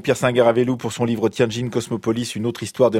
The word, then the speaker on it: Tianjin: Oui, Tianjin.